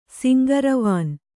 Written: ಸಿಂಗರವಾನ್

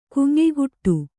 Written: ಗುಂಯಿಗುಟ್ಟು

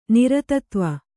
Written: ನಿರತತ್ವ

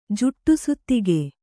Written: ಜುಟ್ಟುಸುತ್ತಿಗೆ

